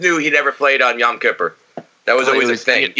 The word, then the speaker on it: Yom Kippur: Yom Kippur.